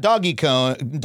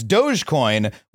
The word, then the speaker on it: Dogecoin: It's not doggy coin, doggy coin. It's Dogecoin, which is just a completely different dumbass way to say that word.